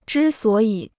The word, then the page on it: 之所以: zhī suǒ yǐ